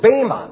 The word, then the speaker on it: bema: And in Romans 14:10, which we looked at, and also in 2 Corinthians 5:10, the word judgment seat is the Greek word bema, literally bema. That's how you would pronounce it in Greek, bema, but we call it bema in English.